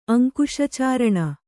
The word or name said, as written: ಅಂಕುಶಚಾರಣ